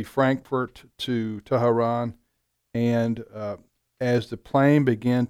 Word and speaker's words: Tehran: Is that how you pronounce it? – That's right. – Tehran.